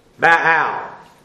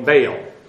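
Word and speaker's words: ba'al (בעל): So 6, the Hebrew verb ba'al means to possess, own, rule over, or marry. Depending upon context. When it is used with the cognate noun, ba'al, normally pronounced by English people, Baal. But ba'al, lord, owner, husband, or master.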